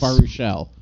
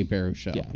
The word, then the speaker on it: Baruchel: Jay Baruch Attell. – Yes, Jay Baruch Attell. Yes. – Baruchel, that's what it is. – Whatever. I can never pronounce his last name. – Jay Baruchel, yeah.